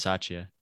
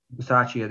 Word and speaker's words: Bisaccia: Rich Bisaccia. – Bisaccia, there you go.